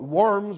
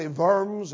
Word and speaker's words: Worms: sometimes we call it Worms, but it's really Worms as regards the German pronunciation.